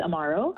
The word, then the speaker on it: Amaro: Amaro. Is that correct? How do you say that? – That's right. It's Amaro.